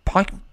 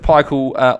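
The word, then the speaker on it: Pikiell: Is it Pikey? – Pikel. – Coach Pikel onto you.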